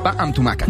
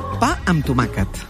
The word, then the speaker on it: Pa amb tomàquet: Pa amb tomàquet. – Muy bien. – ¿Cómo se pronuncia? Enséñame. – Pa amb tomàquet. – Pa amb tomàquet.